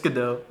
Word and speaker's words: Gadot: It's Gadot. Okay. Actually, it's Gadot. – Yeah. – You do pronounce a T at the end, but it's Gadot. Because it's Israeli.